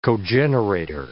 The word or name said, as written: cogenerator